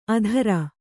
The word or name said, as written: ಅಧರ